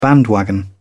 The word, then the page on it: bandwagon: British English